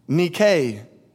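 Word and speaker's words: Nike (νίκη): And from my research, it would have been pronounced in the Greek, Nike, not Nike.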